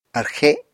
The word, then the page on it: arxe: Apatani